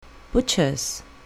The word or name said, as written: butcher's